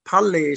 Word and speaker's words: Palli: And Palli is my name that I'm known best for, both in Icelandic and in English.